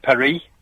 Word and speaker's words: Paris: And Paris in France, they call it Paris. I can say Paris. I don't need to pronounce the S.